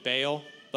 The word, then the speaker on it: Baal: And this Jezebel, she was a Baal-worshipping Sidonian that she even made Baal worship. If you've heard, I just found this out. I used to call it Baal, but if you really pronounce it right, it's supposed to be Baal.